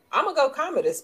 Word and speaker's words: Commodus: All right, I'm going to say his name wrong, but number 33, Commodus Gladiator. Whoever the main bad guy was in Gladiator. – Commodus, there you go.